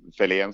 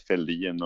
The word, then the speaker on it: Fällén: Så att, eh, allt från eh, ja, Felén som, eh, som är det rätta uttalet, men jag har hetat Fälldin och Selén och ja, det är många olika delar från olika namn som det har varit.